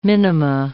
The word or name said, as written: minima